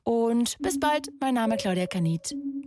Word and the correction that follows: Kamieth: Kamit. Ich kann euch meinen eigenen Namen falsch aussprechen. Claudia Kamit.